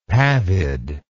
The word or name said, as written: pavid